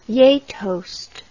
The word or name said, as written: gjetost